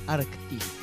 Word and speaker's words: Arktis: Arctis. Se escribe Arktis y el tema era Students Idol.